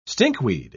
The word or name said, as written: stinkweed